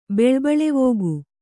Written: ಬೆಳ್ಬಳೆವೋಗು

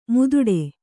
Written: ಮುದುಡು

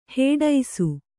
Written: ಹೆಡಸು

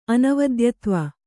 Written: ಅನವದ್ಯತ್ವ

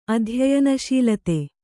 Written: ಅಧ್ಯಯನಶೀಲತೆ

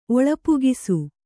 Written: ಒಳಪುಗಿಸು

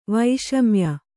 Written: ವೈಷಮ್ಯ

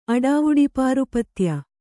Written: ಅಡಾವುಡಿಪಾರುಪತ್ಯ

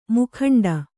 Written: ಮುಕ್ಕಂಡೆ

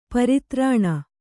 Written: ಪ್ರತಾರಣ

